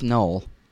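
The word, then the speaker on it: Noël: I would say first Noel to you, Timmy. – Thank you. I appreciate it, Colonel. And it's pronounced Noel.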